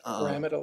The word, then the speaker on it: pyramidal: Pyramidal, is that how you say it? Sorry about that. – I'm sorry? – Pyramidal, I'm sorry. I'm not familiar with the... – Okay, we'll say pyramidal.